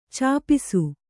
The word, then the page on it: ಛಪ್ಪಿಸು: kannada